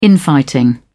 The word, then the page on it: infighting: British English